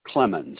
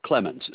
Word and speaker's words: Clemmons: Quentin Clemens, C-L-E-M-M-O-N-S. The charge might have been made by my wife, Maureen Clemens. – And can you spell that first name for me? – M-A-U-R-E-E-N. – Last name? – Clemens, C-L-E-M-M-O-N-S.